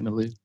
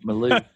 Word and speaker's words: milieu: Actually, funny thing, my wife and I got into a conversation about that because she said that I pronounced it the Swedish way, which is the Swedish word for it is milieu, which is environment, which also is French, and they're related.